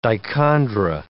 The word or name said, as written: dichondra